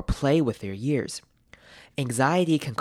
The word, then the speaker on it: anxiety: Anxiety, anxiety 在念完 G 的声音后面要接一个 Z，所以呢 X 这边念成 GZ, GZ，所以标准念法是 anxiety, anxiety, anxiety。